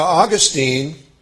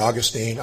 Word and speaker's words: Augustine: Now, Augustine, and I looked this up in a dictionary, and you can pronounce his name either Augustine or Augustine, Augustine or Augustine. I say Augustine, so it's probably Augustine.